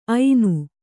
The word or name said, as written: ಐನ